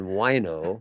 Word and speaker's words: Wineaux: Mind the spelling, W-I-N-E-A-U-X. – Wait, how do you spell it? – W-I-N-E-A-U-X. – Really? – Oh, yes. En français, Wineaux. It's much more sophisticated.